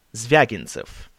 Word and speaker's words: Zvyagintsev: The director of The Return, Exile, and Yelena is Andrei Zvyagintsev.